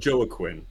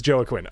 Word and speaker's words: Joaquin: Is it Joaquin? – No, Joaquin. – It is Joaquin. Okay. – No, I'm kidding. It's Joaquin. – Oh, okay. I was like, Joaquin. I actually even looked up the pronunciation earlier, and I was like, I'm pretty sure it's Joaquin, but just in case, yeah. I appreciate the jab.